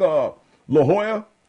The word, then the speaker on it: La Jolla: You know how, like, La Jolla? – Yeah. – How do you think they spell that? – Well, I know, J-O-L-L-A, silent L's. – Well, not really. – No, but I mean, it's pronounced like a Y.